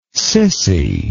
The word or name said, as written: cissy